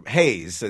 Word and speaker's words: Reis: When João Reis, or Hayes, as it's properly pronounced in Portuguese, tells me that, he's describing to me the conditions of slavery in Brazil.